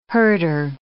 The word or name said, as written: herder